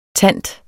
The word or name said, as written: tant